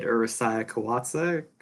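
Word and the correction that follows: Urusei Yatsura: You're talking about the show that it came from? – Yeah. – Ursa Yatsura. – Ursa Yatsura, yeah. Aliens.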